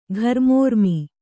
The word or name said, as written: ಘರ್ಮೋರ್ಮಿ